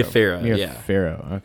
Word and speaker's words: Farrow: Mia Farrow. – Okay. – Like an Egyptian pharaoh. – Oh, gotcha. – Not spelled the same way, but pronounced the same way.